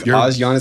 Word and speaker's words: Asiana: I've always called it Asiana, not Asiana. – I think Asiana is correct. Yep. Somehow these podcasts keep getting to the pronunciations. And oddly enough, that's another one where I think the airline sent out a press release, including us, and said, it's Asiana.